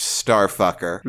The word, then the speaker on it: Starfkr: It's spelled Starfkr. – You know what? I expected that. You don't put vowels in there. It's Starfucker. – Yes, it is pronounced Starfucker.